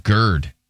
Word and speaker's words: GERD: No, it's called GERD. – GERD. – Rhymes with turd.